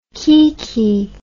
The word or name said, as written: kiki